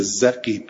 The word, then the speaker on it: زقیب: رقیب، رقیب، رقیب، بل ها اولیشو نه نه درسته، زقیب، زقیب، رقیب نه، زقیب، نه نه زقیب، اولی زقیب، دومی هم زقیبه. بله دومی رو اشتباه خوندم، تلفظ اینام همه تو تفسیر صافی فیض کاشانیه، تلفظ سیش. بله زقیب، دوتاش زقیب.